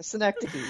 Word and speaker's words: synecdoche: It's a synecdoche? Is that how you say it? – A synecdoche, yeah.